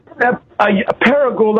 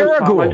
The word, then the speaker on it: Paragould: Eardoodle, Arkansas? – No, Paragould, Arkansas. We've spoke several times. – Paragould.